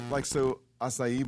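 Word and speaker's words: açaí: I think the only thing that we really make at the house that would be made anywhere else is acai bowls are like a big deal.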